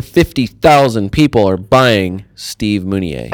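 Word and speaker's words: Mounié: Steve's last name has an acute accent, which is why you pronounce his name Steve Mounier.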